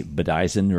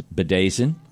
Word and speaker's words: bedizen: It's spelled B-E-D-I-Z-E-N. You would think it would be Bedazin or but it's Bedazin. – B-E-D-I-Z-E-N.